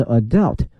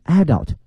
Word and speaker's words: adult: 个孩子们看到了一个不同的世界，比较容易 a different world，后面跟的一个词呢是成人所不能看到的，后面看看这个成人这个词呢是 adult Adult， adult 这个词啊有两个念法，一个是 adult，另外呢一个是 adult 啊，都是可以的。